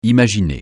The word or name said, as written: imaginer